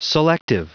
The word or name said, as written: selective